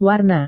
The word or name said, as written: warna